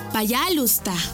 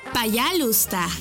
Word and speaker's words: пожалуйста: Y se dice пожалуйста. ¿Cómo? Пожалуйста. Una vez más. Пожалуйста. Y se escribe пожалуйста. P-A-Y-A-L-U-S-T-A.